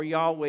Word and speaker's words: Yahweh: It is the covenant name for God, pronounced either Jehovah or Yahweh, as best we can tell.